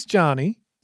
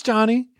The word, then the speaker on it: Jani: My name's Johnny, which I now realize sounds like Johnny, but it is spelled J-A-N-I. – Janie? – My name's Johnny.